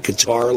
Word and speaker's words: Qatar: And it shows the amount of money that countries like Qatar, I think that's how you pronounce it, right? Qatar, like guitar.